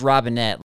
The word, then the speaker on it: Robinet: and it is Robinet. He actually has a video about that as well. So for those who don't know, like, that's how you actually do pronounce his name. A lot of times people, when you're watching them on TV or on YouTube, they don't always like say their name, or maybe it's just spelled on the screen. But that's how you say it.